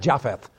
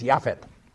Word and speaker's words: Yefet: And again, tries to use the actual Hebrew pronunciation of the name. That's not Japheth. There's no J in Hebrew. It's Yefet. Okay?